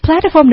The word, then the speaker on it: platform: 对，其次 platform 的重音在第一个音节上是 platform。